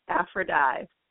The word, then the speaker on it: Aphrodyi: It's Aphrodyi.